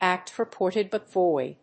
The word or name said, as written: act purported but void